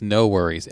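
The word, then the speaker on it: NxWorries: Another album Randolph would like is Yes Loud by NX Worries. Pronounced No Worries. NX Worries, all one word. – Yes Loud. Is that L-A-W-D? – L-A-W-D.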